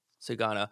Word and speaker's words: Sugano: Tomoyuki Sugano? – Sugano's right. – Sugano.